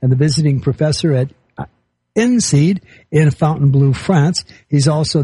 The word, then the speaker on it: INSEAD: It was close. It's INSEAD is the INSEAD. Correct pronunciation.